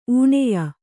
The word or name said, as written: ಊಣೆಯ